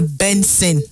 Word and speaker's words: BNXN: But they spell him BNXN, meaning Benson. Now in father name, now in surname be that. So but in just turn him in a way where go they more stylish. Now he making the spell his own BNXN. But the pronunciation na Benson. Now they pronounce the name Benson.